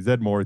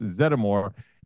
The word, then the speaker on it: Zeddemore: You pronounce the name from the movie as Winston Zeddemore. Some people say Zedmore. It's Zeddemore.